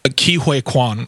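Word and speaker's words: Ke Huy Quan: He had Ki-Hui Kwan. – Ki-Hui Kong? – Ki-Hui Kwan.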